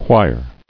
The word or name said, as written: Quire